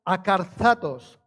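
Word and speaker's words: acarzatos (ἀκάθαρτος): Y esta traducción, esta palabra, cuando la traducimos del griego, es una palabra traducida del griego, se pronuncia acarzatos, acarzatos.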